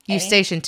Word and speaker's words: Eustachian: E-U-S-T. – Eustachian tubes.